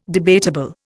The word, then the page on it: debatable: Indian